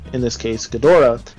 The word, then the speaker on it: Ghidorah: I say Ghidorah. – That's how you say it. Whoever says it Ghidra, Oh, psh, so wrong.